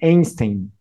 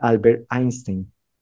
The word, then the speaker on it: Einstein: En español puedes decir Einstein y no hay ningún problema. Acuérdate que en español se puede leer como se escribe sin ningún problema y es correcto, es aceptado por la Real Academia de la Lengua Española. Pero si no estoy mal, se dice Albert Einstein.